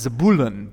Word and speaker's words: Zebulun: We see Jacob, and each with his own household: Reuben, Simeon, Levi, Judah, Issachar, Zebulun, which I looked up and it's actually pronounced Zebulun, but there's no way y'all would let me get by with that.